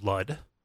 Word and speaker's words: Lud: But the important thing here is to talk about Lud, which rhymes with crud, and the grays and the pubes.